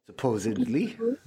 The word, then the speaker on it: supposedly: Supposedly. It's a D, not a B.